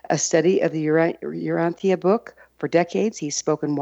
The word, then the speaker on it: Urantia: We use the pronunciation of Urantia. So it's Urantia, Urantia book.